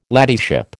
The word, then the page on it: ladyship: American English